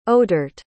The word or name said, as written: odhert